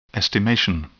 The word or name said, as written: estimation